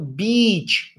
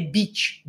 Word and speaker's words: beach: Aí você sabe que é i, com i bem, bem uh, forte, i aberto assim. Então, beach, né? Lembra de biquíni, beach. Pronto, nunca mais vai errar. Lembrou? Ah, é beach ou beach? Eu não sei como é que fala essa palavra. Cara, é beach, beach. Então, lembra de biquíni, é o mesmo i do biquíni. Então, só falar, em vez de biquíni, beach Pronto, acabou.